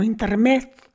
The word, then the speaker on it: intermezzo: Bueno, pues es una palabra, se escribe con 2 zetas en italiano, intermezzo, zo, intermezzo, que significa en italiano literalmente interludio. El plural se dice intermeci, acabado en i.